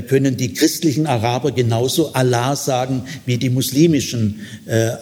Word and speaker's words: Allah: Also das islamische, die islamische Bezeichnung Allah, zweiter Ton, zweite Silbe betont, für Gott liegt auf der Ebene El.